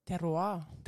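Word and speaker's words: terroir: Kako lepo si to povedal? Tewa. Je to pravilna izgovorjava? – Teroa. – Teroa. Teroa. Potlem pa, je pa Google se zlagal. Google je rekel tewa.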